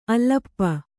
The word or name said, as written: ಅಲ್ಲಪ್ಪ